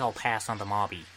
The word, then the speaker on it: mauby: You read the menu and find a word spelled M-A-U-B-Y. You know that there is a drink pronounced Marby because you heard me say Marby. You heard this. So you see this word. There is no other word that starts with M on the menu. So tell me this here. You knew that that was the drink that I was talking about, but you choose to say it wrong. It is Marby.